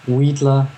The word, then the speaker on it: Weedler: Well, in English, it's sort of hard to pronounce. Weedler.